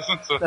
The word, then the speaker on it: Sun Tzu: É assim que fala mesmo? Sun Tzu? – É a primeira pronúncia certa da noite? Não, tá certo, Sun Tzu.